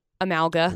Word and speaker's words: Amalga: Rocky Mountain Power has restored power to Almaga. – Amalga. – Whatever. Almaga. – Amalga. A-M-A-L-G-A.